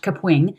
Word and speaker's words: Kapwing: So some people pronounce it Capwing, but I watched, I, went down the rabbit hole of, of YouTube last night and watched a bunch of videos. And so it's pronounced Capwing.